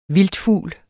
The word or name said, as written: vildtfugl